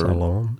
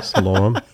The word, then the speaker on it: Siloam: But this tower of, you had said Siloam or Siloam or Siloam. – Siloam. – I'm not sure. – Yes. – Right. – Siloam.